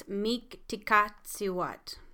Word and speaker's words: Mictecacihuatl: Okay, one of the YouTube things says it's Miktikatsiwat. I hope I said that right.